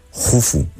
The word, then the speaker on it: Jufu: se escribe Keops, pero se pronuncia Jufu, y casi nadie llama Jufu, porque Herodoto nos lo tradujo del griego y bueno, pues ahí se ha quedado, ¿no?